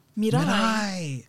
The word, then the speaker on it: Miray: Miray. Like Mariah without the R.